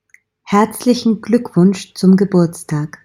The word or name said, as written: herzlichen gluckwunsch zum geburtstag